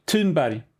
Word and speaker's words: Thunberg: the H, imagine that's not gone. The sound is not in the Swedish language. Thunberg. It's a hard T.